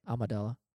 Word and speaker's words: Amidala: Amidala.